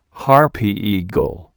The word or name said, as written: harpy eagle